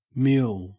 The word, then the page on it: mule: Australian English